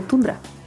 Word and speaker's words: Tundra: Sí, sí, se escribe Tundra, pero Tundra.